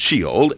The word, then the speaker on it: Sheol: The word hell actually is normally translated from an Old Testament word which is called Sheol, or pronounced Sheol in the Old Testament, and in the New Testament there is an equivalent word called Hades.